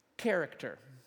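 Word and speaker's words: Character (χαρακτήρ): It's this Greek word that's pronounced like this. Character.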